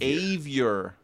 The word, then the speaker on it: Avior: Avior. – That's a hard A, like rhymes with behavior. Bad behavior.